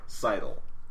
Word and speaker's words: sidle: I will accept that the word is pronounced sidle.